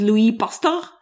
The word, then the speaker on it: Pasteur: This lecture was given by a guy called Louis Pasteur. – Exactly. That's exactly how he pronounced it himself. – Correct, right? – Yeah. – Pasteur.